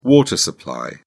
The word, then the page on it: water supply: British English